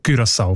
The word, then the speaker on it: Curaçao: Die haben nämlich gerade letzten Dezember die Aussprachempfehlung geändert von Curaçao auf Curaçao, weil sie beziehen sich dort auf Aussprachen-Duden und auf das digitale Wörterbuch von der deutschen Sprache, wo beide eben Curaçao vorschreiben. – Danke vielmals. Jetzt hast du mir den Namen von der Insel gesagt, wie man den ausspricht. Curaçao mit Ü in dem Fall.